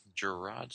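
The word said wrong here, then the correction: Jarrod: Jared. Like the Subway guy. – Jared. Why does he spell it Gerard? – I have no idea. I'm pretty sure it's Jared.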